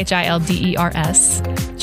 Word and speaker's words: Childers: CHILDERS. Childers, not childers.